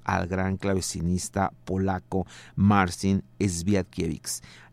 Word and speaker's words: Świątkiewicz: Eh, así se escribe Esguiewix y según lo que investigué se pronuncia Esviatkiewicz.